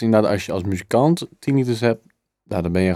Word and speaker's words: tinnitus: Ehm, want Rijer heeft last van tinnitus. Zeg ik dat goed? Ja.